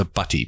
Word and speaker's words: butty: In England, it's a butty, B-U-T-T-Y. Because of their accents, because no one knows how to say the letter T in England. Yeah. I thought they were saying chip butty. – Yeah. – Like B-double-D.